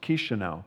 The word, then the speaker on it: Chișinău: How do you pronounce it? It's pronounced Chișinău.